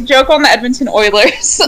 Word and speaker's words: Eulers: Yes, she used to be the host of the podcast Edmonton Eulers, but it's pronounced Oilers.